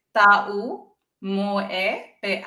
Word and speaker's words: Taumoepeau: You've got to pronounce every vowel. So tau moe peau Tau moe So say.